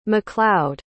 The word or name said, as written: maccloud